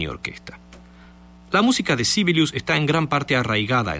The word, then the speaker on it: Sibelius: A Jean Sibelius, o Sibelius como se pronuncia en Finlandia, le debe este país su primera gran aparición en la historia de la música.